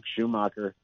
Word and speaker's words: Schumacher: And, you know, I know Steve mentioned quite a bit about Luke Schumacher, that's how you say his name, and how Michigan sees him as being a, like, maybe one of the best tight ends they've ever had.